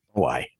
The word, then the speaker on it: Moai: Moai. – Moai. That's what I thought as well. I was going to say, I won't say what I was going to say. Easter Island and its giant Moai statues.